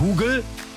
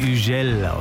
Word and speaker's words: Hugel: Das ist ein französischer DJ, deswegen heißt er nicht Hugel, so wird er nämlich geschrieben. Er wird Hugel ausgesprochen und schon klingt es viel cooler.